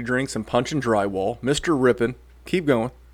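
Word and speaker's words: Rypien: Ripien.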